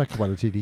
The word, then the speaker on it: Taika Waititi: And Taika Waititi. Taika whatever. How the fuck you pronounce that? – Taika Waititi.